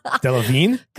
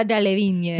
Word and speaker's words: Delevingne: How do you say it? – Delevingne? – Cara Delevingne. – If you're fucking Delevingne. – Yeah, if you're a fucking barbaric American, it's Delevingne.